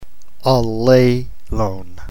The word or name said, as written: allelon